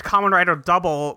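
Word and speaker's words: Kamen Rider W: Well, that guy is named Kamen Rider Double, but it's spelled Kamen Rider and then just W. So take that as you will.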